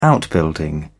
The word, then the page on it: outbuilding: British English